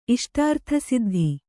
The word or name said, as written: ಇಷ್ಟಾರ್ಥಸಿದ್ಧಿ